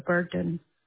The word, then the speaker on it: Burden: Yeah, I would have been known as Deborah Burden. – Okay. How do you spell that? Sorry, but B-U... – B-U-R-D-E-N. – D-E-N.